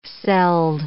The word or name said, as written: celled